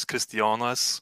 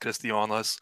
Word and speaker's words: Kristijonas: Okay, so my English like legal name is Christian with a K, but my Lithuanian name is Kristijonas. It's like Christy, spelled Christy Jonas, but pronounced Kristijonas.